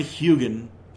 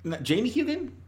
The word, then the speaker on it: Heughan: And then you've got Sam Heughan. I believe that's how you pronounce his name. I've heard it hard. Heughan. Scottish actor, best known for Jamie Fraser on Outlander.